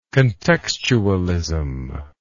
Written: contextualism

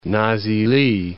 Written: Nazilli

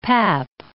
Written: pap

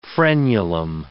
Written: frenulum

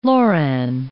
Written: loran

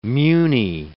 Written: muni

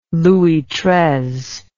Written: Louis Treize

